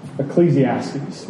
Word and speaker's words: Ecclesiastes: First with Proverbs, last week with Job, and this morning, Ecclesiastes, which is a five-syllable mouthful.